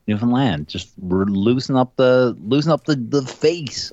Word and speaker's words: Newfoundland: Newfoundland. Understand. Newfoundland. – Don't get nervous when you say it. Just Newfoundland.